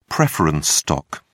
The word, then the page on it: preference stock: British English